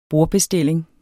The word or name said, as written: BORDBESTILLING